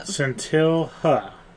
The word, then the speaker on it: Scintilha: It is, the name of the wine is Scintilha. I think I'm pronouncing it wrong. – Scintilha. But you have to say it kind of like, you know.